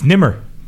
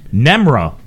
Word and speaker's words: Nemr: Nobody gets it because it's spelled N-E-M-R. – Yeah. – Which for, I've had these pronunciations. Yeah. I've had Nimur, Nimur, Nimur, and then in England, this is the best one. Nemra, which I still don't know how that came.